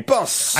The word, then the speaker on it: Krampus: It was definitely two syllables, one vowel. – Yeah. – Krampus.